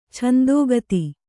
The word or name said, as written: ಛಂದೋಗತಿ